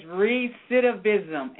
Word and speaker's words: recidivism: How do you say that one? – It's recidivism, and what it basically means is for a person who has been repeatedly incarcerated.